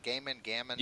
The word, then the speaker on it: Gaiman: Am I saying his name right? By the way, is it Gaiman? – Yeah.